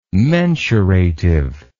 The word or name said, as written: mensurative